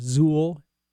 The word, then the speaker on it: Zeuhl: Well, one of the important subgenres of progressive rock is called either RIO or rock in opposition, and its sister style, Zuhl, Z-E-U-H-L is how that's spelled, and then just generally eclectic prog.